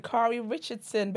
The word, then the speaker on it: Sha'Carri Richardson: Sha'Carri Richardson, baby.